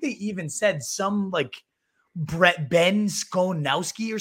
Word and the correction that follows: Skowronek: How do you pronounce dude's name? Skonowski? Skowronek? – Ben Skowronek. – Skowronek. That's what it is.